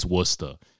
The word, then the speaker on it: Worcester: And it's like, no, it's fucking, it's Worcester. And I'm like, why is it spelled that way then? Why isn't it spelled W-O-O-S-T-A-H? Because of how you say it.